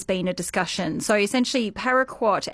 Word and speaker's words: paraquat: We are going to talk, though, about a herbicide, paraquat. I think you can correct me if the pronunciation's incorrect. – No, you got it absolutely right there.